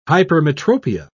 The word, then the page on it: hypermetropia: British English